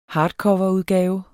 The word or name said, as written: hardcoverudgave